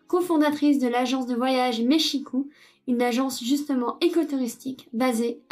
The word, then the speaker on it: Mexico: donc on prononce Mexico et pas euh, Mexico ou Mexico, comme, on pourrait le, penser à la lecture.